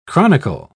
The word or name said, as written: chronicle